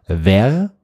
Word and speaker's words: vair: Ver, V-E-R-R-E, bedeutet Glas. Und das Spannende, es gibt ein zweites französisches Wort, das spricht man eigentlich genauso aus, auch Ver, und das schreibt man aber V-A-I-R, und das bedeutet Eichhörnchenfell.